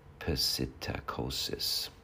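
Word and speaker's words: psittacosis: This is pitocosis. How do you pronounce it? Pesitacosis.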